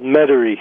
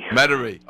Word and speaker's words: Metairie: And how do we pronounce that word? Metairie? – Yeah, the suburb I live in is called Metairie. – Metairie.